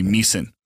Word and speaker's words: Miesen: used to be Meissen. – Yeah. – And it's a German name, but it's spelled M-I-E-S-E-N. So it looks like Meissen. Uh-huh. Or Mason. People would jack it up.